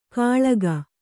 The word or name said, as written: ಖಳಗ